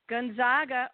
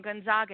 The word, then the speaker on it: Gonzaga: Number one, North Carolina versus number one, Gonzaga. Oh, I said it wrong. Gonzaga. It's Gonzaga.